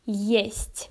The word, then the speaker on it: есть: In Russian, it's gonna be есть.